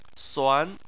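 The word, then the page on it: suan2: tone 2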